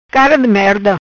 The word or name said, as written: Cara di merda